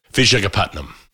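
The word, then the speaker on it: Visakhapatnam: I like your pronunciation of the full city. Do it again for me. – Vijayapatnam.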